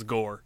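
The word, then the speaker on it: Gorr: The only thing I looked up was Christian Bale's character's name, which was Gore. It rhymes with Thor.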